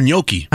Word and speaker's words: gnocchi: Is it gnocchi or gnocchi? – I don't know. I thought the G is silent. – G's are never silent, unless it's lasagna.